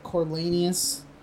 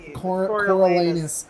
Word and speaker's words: Coriolanus: Corlaneous? Corlaneous, no? – Coriolanus.